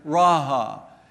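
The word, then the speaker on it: raha (רעה): And so the Hebrew word for shepherd then is raha. And if you were to phonetically spell that, it'd be R-A-A-H.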